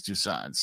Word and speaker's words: Toussaint: And since he they speak French in Haiti, so it's in Toussaint. Haiti. Yeah, it's Toussaint.